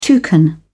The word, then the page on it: toucan: British English